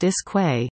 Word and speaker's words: Iskwe: Canadian Indigenous artist Iskwe, pronounced Iskwe, is coming next month to Elliott Lake.